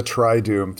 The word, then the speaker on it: Triduum: The Triduum, three times doom. Three days, holy, from Mass of the Lord's Supper to Easter Sunday. – And it's spelled in this crazy Latin way, T-R-I-D-U-U-M.